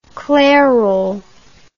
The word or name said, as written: clairol